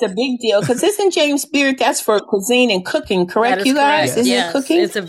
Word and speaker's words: James Beard: So yeah, that was James Bearden Award, wasn't it? – Yep. Yeah, James Beard. – James Beard, I said it wrong.